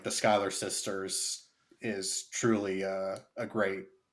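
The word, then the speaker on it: Schuyler: She does get some great songs in this, especially the opening song with the, is it Skylar or Schuler? – It's Skylar. Sisters. It's spelled like Schuler, yeah. But it's, yeah, Skylar Sisters.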